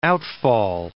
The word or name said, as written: outfall